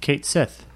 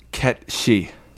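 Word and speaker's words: Cait Sith: Kate Sith. – All right, that's what I called that character for 20 years. – Kate Sith. – It's Ket She.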